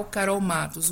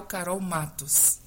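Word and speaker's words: Karol Mathos: oficial Carol Matos. O meu nome se escreve Carol com K, Matos TH. Oficial Carol Matos.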